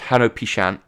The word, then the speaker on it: Hanal Pixan: And just to say, although it's pronounced Hanu Pishan, it is actually spelt H-A-N-A-L-P-I-X-A-N.